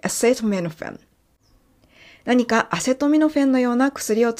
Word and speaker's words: acetaminophen: キャシーはアセトミノフェン。